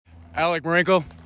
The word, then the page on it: Alec Marenco: al LICK mah REN coe